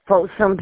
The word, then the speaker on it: Folsom: Ave, Folsom, PA. – You said Folsom? Spell that for accuracy. – Folsom, F-O-L-S-O-M.